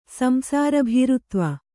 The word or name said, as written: ಸಂಸಾರಭೀರುತ್ವ